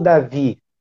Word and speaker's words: David: É David ou Davi? – David é como se escreve, mas ninguém me chama de David. Pode chamar de Davi mesmo, sem problemas.